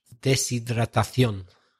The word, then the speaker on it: Deshidratación: Deshidratación. Deshidratación. – Deshidratación. – It's a bit of a mouthful. Yeah, deshidratación.